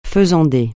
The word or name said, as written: faisandé